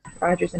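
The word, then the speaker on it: Rodgers: moving quickly along to Best Revival of a Musical, Annie, The Mystery of Edward Drood, Pippin, and Roger and Hammerstein's Cinderella. Hopefully I said that right. – Rogers and Hammerstein. – Hammerstein, sorry.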